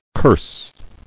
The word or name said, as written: Cursing